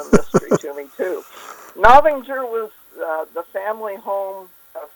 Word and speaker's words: Novinger: Novington, Missouri, in my grandmother's bedroom. – How do you spell that, Novington? – N-O-V-I-N-G-E-R. – Novinger. Interesting. – Novinger. – I-N-G-E-R.